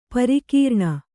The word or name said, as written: ಪರಕರಣ